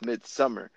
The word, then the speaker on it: Midsommar: I called it Midsommar, and somebody's like, "What the hell are you saying?" I'm like, "Bro, just pronouncing it. Did I say it wrong?" They're like, "Yeah, it's Midsummer." I'm like, "Then why the hell is there a fucking O?"